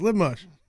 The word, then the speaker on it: liver mush: I even said it right. It's lib mush.